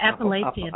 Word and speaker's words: Appalachian: I was hiking through the Appalachian? – The Appalachian Mountains.